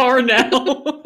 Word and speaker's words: Parnell: In that particular TED Talk, again, Bailey Parnell, if you haven't seen it, I just butchered that so fucking bad. – Like, Parnell.